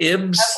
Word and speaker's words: Ybbs: I think they say it Ybbs. – Okay. Y-B-B-S.